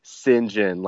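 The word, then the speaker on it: St John: So I looked this up, and actually there's an old pronunciation of Saint John as Sinjin, like Saint John said fast. Saint John, Sinjin.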